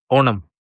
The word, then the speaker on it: Onam: Onam. – Onam. – O-N-A-M. – Onam. – Onam, mhm. Genau, ja. So, und Onam seit, das Essen ist die beste. Während des Onam-Zeit, Mittagessen auf dem Teller. So, Onam, man kriegt auch diese verschiedene Payasam.